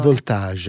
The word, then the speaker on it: Voltage: Recentemente è stato pubblicato anche questo Voltage, credo si debba pronunciare in francese o in inglese, come lo pronunciate voi questo? – Io francese per deformazione. – Voltage, sì.